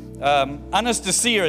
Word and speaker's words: Anastasia: But then New Zealanders, we go Anastasia. I've got a friend called Anastasia. Anastasia is how you should pronounce it.